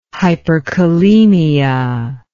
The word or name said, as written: hyperkalemia